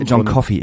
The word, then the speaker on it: John Coffey: Dann habe ich mich gefreut, dass John Coffin halt gespielt, mhm, haben. – John Coffey im Übrigen. – John Coffey. Ich habe mir gestern gesagt, das wird John Coffin ausgesprochen. – Auf keinen Fall, John Coffey.